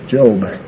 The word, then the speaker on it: Job: Over in Job 16:21, this is the only word that when you capitalize the first letter, it changes the pronunciation. You know, when it's a small J, it's Job.